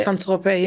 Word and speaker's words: Saint-Tropez: So I was doing, like, the social media, videoing, photography, like, and then I also had the opportunity to go, is it Saint-Tropez? Is that how you pronounce it? – Saint-Tropez, yeah.